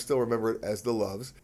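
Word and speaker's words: Loews: If you ask anybody who remembers it, it was opened up as a Lowe's hotel, but nobody in Monte Carlo or Monaco ever pronounced it that way. They still call it the Loves. It's the Loves Hotel.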